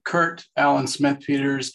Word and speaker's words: Kurt Allen Smith Peters: Okay, Kurt Allen Smith Peters, K-U-R-T-A-L-L-E-N. S-M-I-T-H-P-E-T-E-R-S.